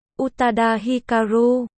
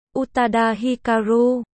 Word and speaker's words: Utada Hikaru: Utada Hikaru, Utada Hikaru. Cô giáo lộ ra vẻ ngạc nhiên. Sau đó vỗ tay khen ngợi. Cô bạn này phát âm rất chuẩn.